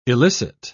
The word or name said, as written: elicit